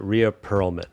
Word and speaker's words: Rhea Perlman: It's spelled that, but I believe you pronounce it Rhea Perlman. – Rita Perlman. – Rhea. – But we're going to... Rhea. Rhea? – Yeah. – Are you serious? Rhea Perlman? – Yeah. Rhea Perlman.